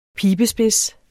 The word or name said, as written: pibespids